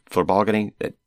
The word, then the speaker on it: flibalgany: Flibalgany? How did you say that? – Flibalgany.